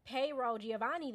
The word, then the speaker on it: Payroll Giovanni: for a Thanksgiving event, and it was Payroll Giovanni there.